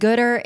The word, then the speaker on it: Goodr: And Goodr is, I'm going to say this wrong, it is a technology-based food waste management company. – You said it right.